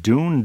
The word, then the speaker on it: Doune: So there's nothing better than pictures on the radio of his travels to Balmoral, Queen Elizabeth's getaway in Scotland, Balmoral Castle, and I think it's pronounced Dune, D-O-U-N-E, Castle, where Monty Python and the Holy Grail was filmed.